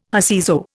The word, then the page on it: whoseso: Indian